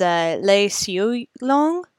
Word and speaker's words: Lei Siu Long: Yeah, and I think that would be like the Mandarin pronunciation, whereas the Cantonese is Lei Siu Long. – Huh. – L-E-I-S-I-U-L-O-N-G.